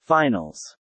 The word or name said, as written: finals